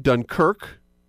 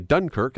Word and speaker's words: Dunkirk: Which is actually pronounced two different ways. If you're a Canadian, most of you say Dunkirk. That's what I heard. But if you're Scottish or from in the old country, it's Dunkirk.